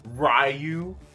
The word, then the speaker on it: Ryu: It's pronounced Ryu.